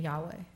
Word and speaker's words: Yahweh: So how do we pronounce YHWH? We don't know。 – 那后来人就把它加几个字啊，变成耶和华。 – So people eventually added vowels, and that's how it became Yahweh.